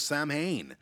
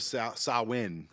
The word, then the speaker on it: Samhain: Halloween, the holiday, I mean, it has its roots in ancient Celtic festival of Samhain.